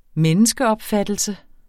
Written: menneskeopfattelse

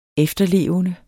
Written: efterlevende

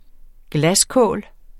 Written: glaskål

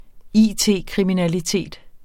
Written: it-kriminalitet

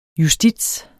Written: justits

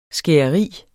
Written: skæreri

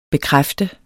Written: bekræfte

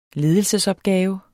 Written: ledelsesopgave

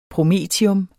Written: promethium

